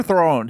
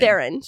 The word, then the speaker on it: Theron: Is it Theron or Throne? How do you pronounce her last name? – Theron. Charlize Theron.